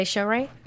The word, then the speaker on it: Shohreh: So yes, with Punisher, and forgive me for butchering this name, Shohreh Aghdashloo. She is an Academy Award nominated actress. She has been cast in. Did I say Shohreh? – Yep. – Awesome. – Yep. – Score for Shohreh Aghdashloo.